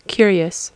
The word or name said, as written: curious